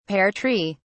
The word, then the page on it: pear tree: British English